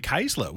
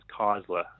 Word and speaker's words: Kaesler: They typically sort of pronounce it as Kaesler. Yeah. Um, so I think that's the correct, pronunciation, but right. um, obviously the Austra- Australian inflection uh, has changed it to Kaesler.